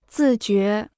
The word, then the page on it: 自觉: zì jué